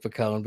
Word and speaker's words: Picone: Is it pronounced like Picone? – My actual name is Nick Picone, but I've been called Pinecone for years.